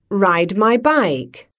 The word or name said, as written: ride my bike